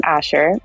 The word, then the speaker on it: Ascher: And then Storm Ascher, and Ascher is spelled A-S-C-H-E-R.